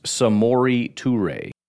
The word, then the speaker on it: Samori Toure: Pronounced Samori Toure. Kind of as you would expect, but just so you know.